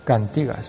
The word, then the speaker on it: Cántigas: Estamos ante la capilla de Santiago, donde reposa la imagen pétrea y policromada de la Virgen de las Cántigas. Cántigas, se dice así, que ahí está la tilde.